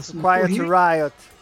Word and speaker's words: Quiet Riot: Ah, a gente vai começar com The Darkness, depois a gente vai com o Quiet Riot, se a minha pronúncia estiver errada. Quiet Riot. – Quiet Riot.